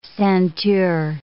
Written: ceinture

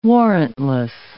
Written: warrantless

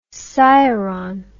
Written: sciron

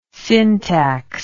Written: syntax

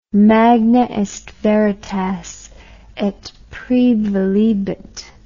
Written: magna est veritas et praevalebit

